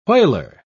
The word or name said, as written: whaler